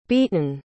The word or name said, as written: beeton